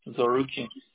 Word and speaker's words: Zarokian: Zarukian. – Can you spell it for me? – Z-A-R-O-K-I-A-N.